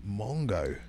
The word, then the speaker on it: Mungo: Is it Mungo? – It's M. – It's definitely Mungo. – Mungo, yeah. That's what I meant.